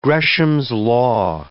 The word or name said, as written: Gresham's law